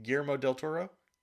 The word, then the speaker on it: Guillermo del Toro: Guillermo del Toro? – Yeah.